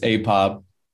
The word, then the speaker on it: apob: It's apob.